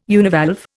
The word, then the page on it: univalve: Indian